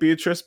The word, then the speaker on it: Beatrice: It's spelt like Beatrice, but it's pronounced Beatrice.